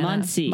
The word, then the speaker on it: Muncie: Muncie.